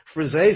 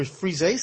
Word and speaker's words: frisée: So some salads suck, but that frizzy salad, and thanks for pronouncing it properly.